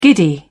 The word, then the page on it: giddy: British English